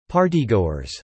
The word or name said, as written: partygoers